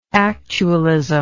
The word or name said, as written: actualistic